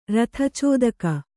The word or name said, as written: ರಥಚೋದಕ